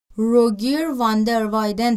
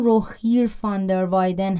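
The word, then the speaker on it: Rogier van der Weyden: در مورد تلفظ اسمش همینجا بگم که منابع انگلیسی اسمش رو روگیر واندر وایدن تلفظ میکنن چون با جی نوشته میشه و اونورم با وی نوشته میشه ولی تلفظ فلاندریش همون روخیر فاندر وایدن هست یه چیزی بین خ و اگه در منابع دیدین اشتباه نکنین بیشتر کارهای فاندر وایدن مذهبی هم.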